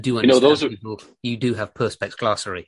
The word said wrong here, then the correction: perspicacity: You have perspex glossary? Is that what it is? Perspex glossary. Well, perspicacity.